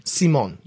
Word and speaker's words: Simone: She pronounces it with kind of a Z. – Simone.